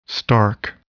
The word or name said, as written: stark